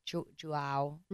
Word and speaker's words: JWoww: So then, and then Hannah is pissed that Conrad and JWoww are, JWoww, mm-hmm.